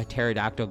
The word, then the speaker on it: pterodactyl: Can't you hear a pterodactyl go to the bathroom? Because the P is silent. Get it? The P in pterodactyl. P-T-E-R-O-D-A-C-T-Y-L.